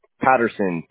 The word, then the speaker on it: Patterson: B-E-A-U. – Okay. B-E-A-U. And your last name, Bo? – Patterson. P-A-T-T-E-R-S-O-N.